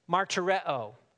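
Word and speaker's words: martyreo (μαρτυρέω): In the Greek, it's pronounced martyreo or marturion. It's where we get the word martyr from.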